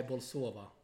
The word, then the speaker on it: Bolsova: 2-2 mot Bolsoro, var det va? Nej, Bolsova. Bolsova.